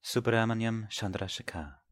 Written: Chandrasekhar

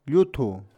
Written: Lluthu

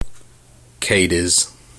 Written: Cadiz

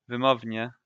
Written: wymownie

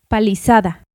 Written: Palizada

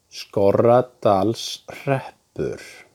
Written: Skorradalshreppur

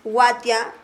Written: Watia

